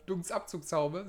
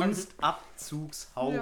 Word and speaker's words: Dunstabzugshaube: Dunstabzugshaube.